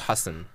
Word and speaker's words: Hasan: So I know it's Hasan.